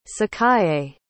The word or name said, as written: sakaye